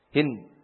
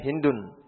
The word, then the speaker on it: Hind: Hind ini maksudnya ha nun dal. Hind. Kalau tidak ada lanjutannya dibacanya Hindun. Hind binti Surair.